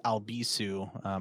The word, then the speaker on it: Albisu: Oh, you can follow me everywhere at Alex Albisu. My last name is spelled A-L-B as in boy, I-S as in Sam, U.